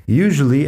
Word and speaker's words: usually: Fíjate en la pronunciación usually, la S ahí al principio se pronuncia como zh, usually. Zh es un sonido poco común en inglés, pero existe en algunas palabras.